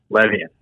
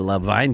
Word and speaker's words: Levien: First of all, his name is, we pronounce the name Levine, but Well, then they got to put the E on the other side of the N because it's spelled L-E-V-I-E-N. – I agree, man. He's been my best friend since we're 14, 15 years old, and I've been telling him for a long time that name is Levian. But he insists on calling it Levine, something about honoring the way his dad and grandfather, great-grandfather all pronounced it.